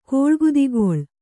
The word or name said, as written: ಕೋಳ್ಗುದಿಗೊಳ್